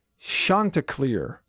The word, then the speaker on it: Chanticleer: The proper pronunciation is Chanticleer.